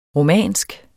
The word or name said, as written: romansk